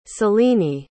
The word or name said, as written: selini